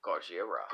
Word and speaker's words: Gojira: They pronounce it... – Gojira. – Gojira. And we say... – Godzilla. – Yeah, that's exactly what it is.